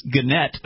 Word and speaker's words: Gannett: I was mispronouncing it earlier, we had Josie call in Virginia to correct me, it's Gannett, apparently.